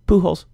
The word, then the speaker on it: Pujols: How do you pronounce that? – Pujols.